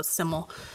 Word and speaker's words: SYML: Simmel, his name is pronounced S-Y-M-L, so Simmel.